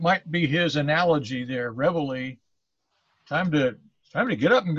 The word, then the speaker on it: reveille: It's spelled R-E-V-E-I-L-L-E. So it's Revel for Radicals. – Well, I would pronounce it Reveille. – Okay. I'll take your pronunciation over mine. – The reason I say that is because in the military, Reveille is a bugle call to get up.